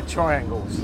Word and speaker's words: triangles: I have to say they're triangles. – A triangle, yeah, of course. Okay. – It's a pronunciation thing, but it is quite important. Yeah. Otherwise it's the angle of a tree, which is really weird.